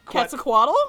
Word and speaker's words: Quetzalcoatl: Quetzalcoatl? – Quetzalcoatl.